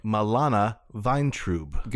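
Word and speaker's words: Milana Vayntrub: Milana Weintraub.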